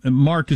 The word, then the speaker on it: marque: And Mark is spelled M-A-R-Q-U-E. He pronounced it Mark. I would have pronounced it probably Marque or something if I was reading it, but he said letters of Mark. And it was very popular during the Age of Sail, but S-A-I-L.